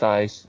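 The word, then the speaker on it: Ys: You mean say Eyes, you mean the game that's spelled Y-S, right? – Yes, it's pronounced Eyes. I know, I had to force myself to say Eyes, even though it's...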